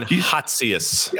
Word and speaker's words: Hatzius: Goldman's strategist, Heitzis, is that how you pronounce it? – Jan Hatzius.